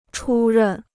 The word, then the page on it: 出任: chū rèn